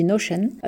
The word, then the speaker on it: Notion: Donc ce logiciel, c'est Notion, Notion, ça s'écrit Notion, mais ça se dit Notion.